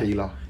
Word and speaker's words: Keeler: Say the surname properly now. You've learned. Come on there. – Keeler. – There you go, lad. That's how you say it.